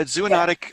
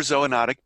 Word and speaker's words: zoonotic: nobody really had heard the word zoonotic. Am I saying it right? Zoonotic? – Yeah, zoonotic, yeah. Or zoonotic, both are correct.